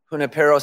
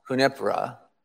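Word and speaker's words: Junípero: Family, today we celebrate, I'm in white, we celebrate the great Saint Junipero Serra. I've heard it pronounced Junipera, but we know who we're talking about.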